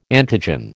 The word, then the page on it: antigen: American English